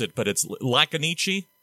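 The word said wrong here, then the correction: Lajkoniki: Is that, am I doing it right? – Lakoniki.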